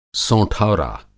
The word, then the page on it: सोंठौरा: سونتھورا